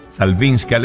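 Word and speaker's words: Szałwińska: se escribe S-Z-A-L-W y después, como suena, Zajwińska.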